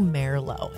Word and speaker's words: Merlot: Well, it's Merlot. M-E-H-R. If you're, you know, phonetically sounding it out, it's actually Merlot. It's very soft, not her.